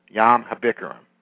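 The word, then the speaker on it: Yom HaBikkurim: They both go together, and Yom Habikurim, that's the way I'm going to pronounce it, Yom Habikurim,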